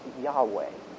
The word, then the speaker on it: Yahweh: The letters are YHWH, if you've ever seen that, and it's pronounced Yahweh, or a different version of that, depending on how native a Hebrew speaker you are.